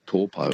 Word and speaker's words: Taupo: When I was in New Zealand, there was Lake Taupo. Now it's called Taupo. – Taupo. Yeah, that's right. It's just the vowel pronunciations are a bit different. To they don't really have that owl sound like cow meow.